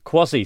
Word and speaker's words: Kwaussie: Quasi, so not like quasi-independent or quasi-judicial. – That's a good point, as in Q-U-A-S-I. – Yes. – As in like half or semi. – Yes. – No, this is spelt K-W-A-U-S-S-I-E.